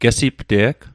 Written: gesipte'g

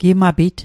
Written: gimapit

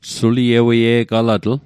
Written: sulieweie'galatl

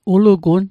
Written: ulugun

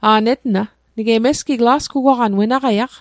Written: lasgugwaqan

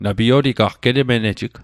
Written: getmenejig

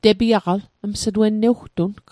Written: tepiaq

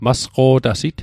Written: masqo'tasit

